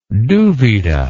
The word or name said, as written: duvida